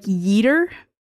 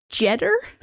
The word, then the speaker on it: Jeter: And I say funny last name because the way it's spelled, not because that I keep wanting to say that it's like Yeeter because it starts with a J, but the J would be pronounced like a J. So it's like Jeter.